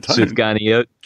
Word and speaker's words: sufganiyot: How do you say the donuts in Hebrew? Do you know this word? – I don't. – Sufganiyot. – Sufganiyot.